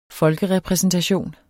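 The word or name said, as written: FOLKEREPRÆSENTATION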